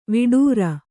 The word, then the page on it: ವಟ್ಟಾರ: vaṭṭāra